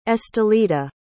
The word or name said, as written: estelita